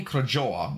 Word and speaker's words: Krajowa: The Army Krajowa, which was, that's the Polish pronunciation here, abbreviated AK or Home Army, was the dominant Polish resistance movement in World War II in German-occupied Poland.